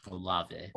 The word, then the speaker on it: Olave: Chris Olave, is that how you say it? – Olave.